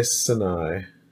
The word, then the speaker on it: Sinai: Is that the English pronunciation? Sinai. Sinai. – I'm going to say Sinai.